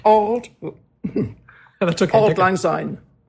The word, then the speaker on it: Auld Lang Syne: Is that true of Auld Lang Syne? – That I don't know. – How do you pronounce those words, by the way, properly? – Auld Lang Syne.